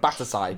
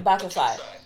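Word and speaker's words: Battousai: But, like, so fake bat, how do you say it with... – You've made me forget it. Batasai. – Batasai.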